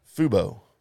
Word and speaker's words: Fubo: And coming soon, RHS TV, right, on FUBU, anywhere you do your streaming. – What's FUBU? – Did I say it wrong? – FUBO.